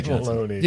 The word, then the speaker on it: cannelloni: Pass the cannelloni. – Cannelloni.